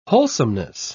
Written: wholesomeness

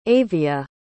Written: avia

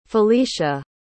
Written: felicia

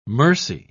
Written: mercy

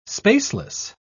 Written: spaceless

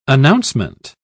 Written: announcement

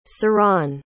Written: siran

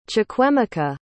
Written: chukwuemeka

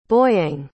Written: Boyang

